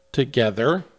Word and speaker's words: TOGETHXR: But Together is spelled TOGETHXR. Together. – Uh-huh. – The X in place of that final E.